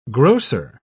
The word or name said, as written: grocer